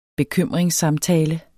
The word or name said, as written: bekymringssamtale